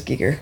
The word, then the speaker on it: Giger: I was looking up how to correctly pronounce Giger, which is Giger.